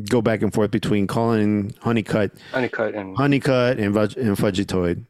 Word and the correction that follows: Fugitoid: I'm not even sure I'm pronouncing Fudgetoid correctly. – Fugitoid. – Is it like Fugitoid? Fugitive? – Okay, Fugitive.